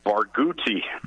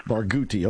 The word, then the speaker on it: Barghouti: Barghouti. – Barghouti.